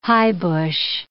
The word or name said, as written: highbush